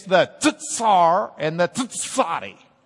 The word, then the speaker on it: tsadi: Pronounce the T and the Z. Tsadi. Come on. Tsadi. We actually have that in an English word. Sort of an English word. The tsar. Do you ever wonder why the tsar starts with a ts? Because it's the tsadi. Okay? So you pronounce it like the tsar. If you're from Lubbock, you can just say czar. And you can just say zadi.